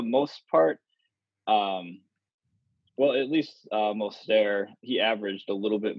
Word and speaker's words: Mostert: Mostert? – Robert Morset. Marstet. – Raheem Mostert.